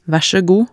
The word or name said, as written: vaer saa god